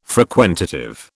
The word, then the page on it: frequentative: British English